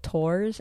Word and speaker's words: Thors: No, I guess I'm going with the Japanese pronunciation, because I think, like, obviously the God of Thunder, they pronounce as Thor, but... – That's fair. No, that's fine. Yeah. I'll continue with Thors, and you can continue with Tors, and we'll just know we're talking about the same person.